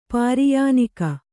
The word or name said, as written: ಪರ್ಯಾಣಕ